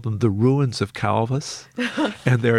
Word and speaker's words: Calvus: named Calvus, or Calvus, I guess the Romans would have said. They pronounced their V's as W, Calvus.